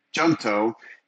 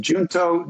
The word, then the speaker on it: Junto: They came up with J-U-N-T-O. Depending on who you ask, it can be pronounced Junto, Junto, or Junto, where the J is not pronounced at all. Anyhow, we needed a new name for our little group here in the Valley of the Sun.